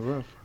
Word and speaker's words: Ruff: Is that Howard Ruff? Is that how you say his name? – Sure, yeah, Ruff.